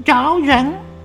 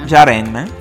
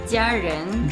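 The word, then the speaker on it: Jiaren (家人): Il pinyin giusto è Jia. – Già. – Ia. – Già. – Ren. – Jiaren. – Jiaren. – Jiaren.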